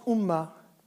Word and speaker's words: UMA: Ce qui est subtil pour les arabisans ici, c'est que Umma, donc UMA, se prononce Umma.